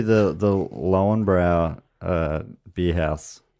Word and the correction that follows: Löwenbräu: At Oktoberfest, the Lauenbrau, or I'm sure it's pronounced totally like Löwenbräu or something,